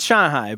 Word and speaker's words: Shanghai: Chinese people, it's Shanghai, but everyone in America says Shanghai, so I'm just kind of saying it's appealing to our audience.